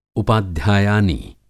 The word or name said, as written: उपाध्यायानी